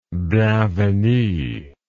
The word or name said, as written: bienvenue